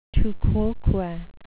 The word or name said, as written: tu quoque